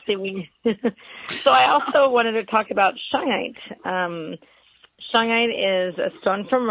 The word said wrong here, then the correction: shungite: You need to spell that. – S-H-U-N-G-I-T-E. – Shungite. There you go.